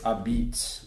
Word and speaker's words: apizza: So the pizza here is called, for your listeners, it's spelled A-P-I-Z-Z-A. It looks like a pizza, but it's a beats.